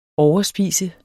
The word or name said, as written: overspiser